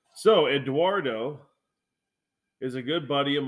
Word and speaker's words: Eduardo: Isn't there a G in there? Have I been saying it wrong my whole life? Is it Eduardo? It's not Eguardo? – No, it's Eduardo.